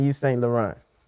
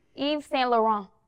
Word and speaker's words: Yves Saint Laurent: East St. Laurent. – Try it again. – Mm-hmm. – That's how they pronounce it when you walk in the store. – Yves Saint Laurent. – Yves Saint Laurent. – Yeah. – No t. – Yeah. – Don't put the t.